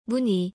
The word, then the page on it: 무늬: korean